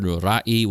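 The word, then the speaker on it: ra'y: It's written R-A apostrophe Y, but it's pronounced ra'i, And this is because that apostrophe that you see is the letter Hamsa in Arabic, and you have to pronounce it.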